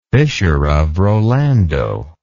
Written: fissure of rolando

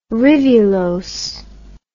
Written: rivulose

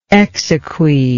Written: exequy

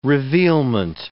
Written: revealment

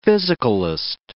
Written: physicalist

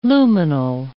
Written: lumenal

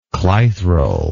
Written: clithral

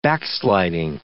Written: backsliding

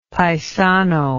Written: paisano

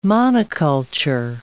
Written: monoculture

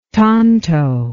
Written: tanto

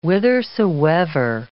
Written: whithersoever

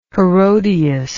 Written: herodias

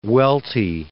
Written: Welty

